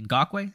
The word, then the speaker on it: Ngakoue: Ngakoue, it's not hard to say, but he just can't play the run.